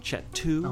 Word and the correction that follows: Chateau: You pronounce Chateau. The word is Chateau.